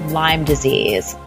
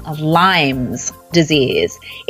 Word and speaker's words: Lyme disease: Today, we're going to be talking about Lyme disease. First of all, if you learn one thing from today's ER Vet episode, it is pronounced Lyme disease, not Lyme's disease.